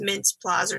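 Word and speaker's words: Mintz-Plasse: I always say his last name wrong. I think it's Mintz Plaz or something.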